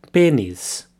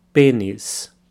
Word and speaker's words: pênis: But before we look at these slang words, let's start with the Portuguese for penis, which is pênis. So a slight difference in pronunciation there. The E has a circumflex accent over it, which makes it an E sound. Pênis pênis.